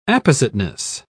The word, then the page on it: appositeness: British English